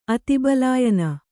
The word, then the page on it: ಅತಿಬಲಾಯನ: Kannada